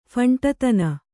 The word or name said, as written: ಫಂಟತನ